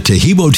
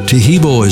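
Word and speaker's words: Taheebo: Tejibo is spelled T-A-H-E-E-B, like boy, O, then the word T, and then the word club.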